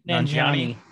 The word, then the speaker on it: Nanjiani: Kumal uh, Nanjini. – Nanjiani. – Yeah.